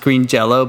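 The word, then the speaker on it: Green Jellö: So it's pronounced Green Jello, but it's spelled differently.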